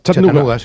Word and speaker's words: Chattanooga: ¿Se pronuncia así en Chattanooga? – Chattanooga. Sí, Chattanooga City.